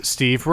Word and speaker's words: Steve: Hello, my name is Steve Rowell. S-T-E-V-E space R-O-W-E period.